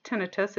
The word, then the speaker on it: tinnitus: It is pronounced tinnitus. It's spelled T-I-N-N-I-T-U-S. It's oftentimes mispronounced as tinnitus. As a reference, things that end in the word in the spelling I-T-I-S are usually related to inflammation, and tinnitus is not an inflammatory process.